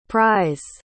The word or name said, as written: pries